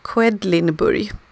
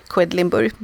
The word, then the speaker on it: Quedlinburg: Quedlinburg. Det är jättesvårt att uttala, det börjar liksom stavas med Q-U-E-D-L-I-N-burg, du förstår ju. Det går liksom inte att säga det på ett bra sätt, Quedlinburg.